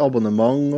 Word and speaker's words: abonnement: Det heter abonnement, og så ending uttales ang.